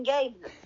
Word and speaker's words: Gaye: Oh, so it's not like Gabe. I gave you a dollar. – No, it's Gaye, like the sexual orientation.